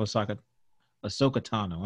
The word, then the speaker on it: Ahsoka Tano: And I think everything from the introduction of Ahsoka Tano, I'm saying that correctly, right? – Yes.